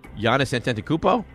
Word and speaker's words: Giannis Antetokounmpo: Giannis Antetokounmpo? – Yeah.